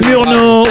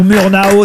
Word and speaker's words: Murnau: C'est Murnau ou Murnau, si vous voulez le prononcer effectivement en français ou à l'américaine, mais au départ c'est Friedrich Wilhelm Murnau, F.W.